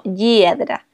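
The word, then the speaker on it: Hyedra: que es H, Y y latina E, D, R, A, como Yedra.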